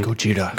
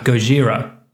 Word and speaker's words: Gojira: And I noticed because Godzilla is referred to in this by the American characters as Godzilla, but by the Japanese characters as its original pronunciation, Gojira. – Gojira, right?